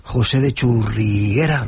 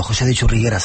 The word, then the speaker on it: José de Churriguera: José de Churriguera. ¿Lo he dicho bien? – Bien. – De Leganés. Tengo que repetirlo. José de Churriguera.